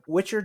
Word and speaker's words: Witcher: I meant to ask, is that Witcher? Did I say that right? – You did, like good. Halloween Witch.